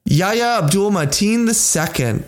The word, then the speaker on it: Yahya Abdul-Mateen II: Yahya Abdul-Mateen II. – I am so proud of you for saying that correctly.